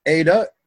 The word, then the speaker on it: eta: As I mentioned before, we learn in class that it is pronounced like a long A. Eta is like A in name.